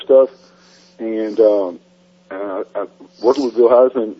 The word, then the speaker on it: Hinzman: I think it's pronounced Hinsman. H-I-N-Z-M-A-N.